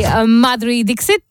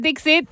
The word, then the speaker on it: Madhuri Dixit: And the artist of the week this week is the one and only Madhuri Dixit. Yep, that's how you say her name. It is Dixit from Ajana Chale.